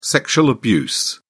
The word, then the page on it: sexual abuse: British English